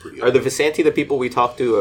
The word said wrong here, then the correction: Vistani: Or it's Vistani. Sorry, I'm mispronouncing this whole time.